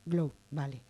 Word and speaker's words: Globe: Globe, vale. La E no se pronuncia.